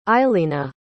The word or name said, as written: eilena